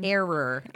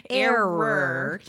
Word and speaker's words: error: It sounded like era. – I know. That's the whole point. – I'm like, that's where you get. That's where the South comes in. – Error. I should have pronounced it a little bit better.